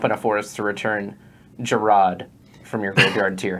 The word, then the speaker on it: Gerard: It's technically pronounced Gerard, but everyone calls him Jared.